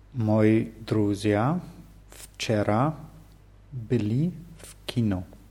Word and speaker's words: были: Hierbei der Pluralform von были ist die Betonung auf ü wieder.